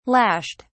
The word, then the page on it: lashed: British English